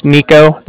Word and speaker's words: Niko: Okay, yeah, like I said, my name's Nico. That's spelled N-I-K-O. And I'm calling from the Bank of Cardiff. That's spelled C-A-R-D-I-F-F, as in Frank.